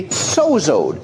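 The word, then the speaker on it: sozo (σῴζω): S-O-Z-O, pronounced sozo.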